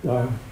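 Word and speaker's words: Doire: how do you pronounce it? – Dor.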